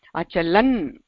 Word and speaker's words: अचलन्: तत्र भेदं पश्यामश्चेत्, भेदं पश्यतु, अचलन् अचलन् इति कथनेन तत्र नकारस्य अन्ते दण्डः अस्ति, नकारस्य अन्ते दण्डः, दण्डः इत्युक्ते स्ट्रोक्, स्ट्रोक् अस्ति इति कृत्वा तत्र उच्चारणं भिन्नं भवेत्, अचलन् इति भवति, किन्तु अचलत इति भवति चेत् अन्ते तत्र हल्वर्णः नास्ति, अन्ते तत्र अकारः अस्ति, अचलत।